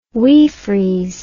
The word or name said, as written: wee frees